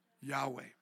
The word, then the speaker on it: Yahweh: So you're right in that way, but how do we pronounce it? Starts with the Y, ends with an H. Yahweh. That's our best understanding of how to pronounce it, right?